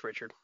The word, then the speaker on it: Richard: Is it Richard? – I'm sure it's Richard.